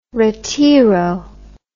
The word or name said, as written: retiro